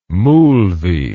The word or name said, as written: moolvee